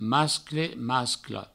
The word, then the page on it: mascle: mascla; masclo